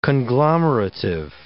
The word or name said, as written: conglomerative